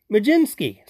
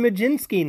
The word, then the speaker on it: Mlodzinski: So I sent to him, how would you pronounce M L O D Z I N S K I? And both of them responded pretty darn close to Majinski.